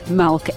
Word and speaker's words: Malch: I mispronounced his name. It's Malk, M-A-L-C-H.